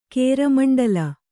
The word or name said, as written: ಕೇರಮಂಡಲ